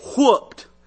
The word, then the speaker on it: whooped: If you're saying it without an H in it, you're saying it wrong. They get whooped.